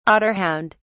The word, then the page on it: otterhound: British English